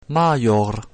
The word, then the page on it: maior: latin